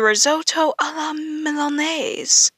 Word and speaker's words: risotto alla milanese: And I asked on Google if that's how it's pronounced, but according to Google, I am pronouncing it right. Yay. So, this is how to make the risotto alla milanese, which is basically Milan-style rice.